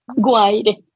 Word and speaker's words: Guaire: Y nunca sé si pronunciarlo como Guaire o Guaire, pero no tiene acento, así que es Guaire.